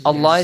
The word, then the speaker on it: الله: Lam is pronounced heavily. والله بصير بالعباد. Right? والله بصير بالعباد. Okay? – Yes. – Allah yes.